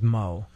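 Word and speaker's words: Meaux: M-O-E. – All right. – It is. That's right, Mo. – Well, what's funny is... My dad's last name is Mo, but it's M-E-A-U-X. – Really? – Yeah. – And why is it pronounced Mo if it's spelled that way? – I think it's French. – It's like a French spelling.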